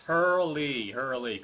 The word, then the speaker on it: Hurley: Yes, Hurley, because before I was pronouncing it Hurl, like hurl a stone or something like that. Because a lot of times with names like that, the E is like silent.